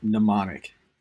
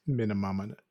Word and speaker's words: Mnemonic: Oh my God, I said it right the first time. How about that, you guys? – Yeah. – The over-under on the number of times I mispronounce that word in this podcast is probably 15 at least. – Mnemonic. – Yeah, I know. Not an easy word to read or pronounce.